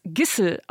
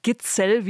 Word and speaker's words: Gitzel: Eigentlich wird er Matthias Gissel ausgesprochen. Habe ich ihn auch gefragt, ob wir das hier so einführen sollen. Da hat er aber gesagt, nein, in Deutschland sagt einfach jeder Gitzel, wie er auch geschrieben wird.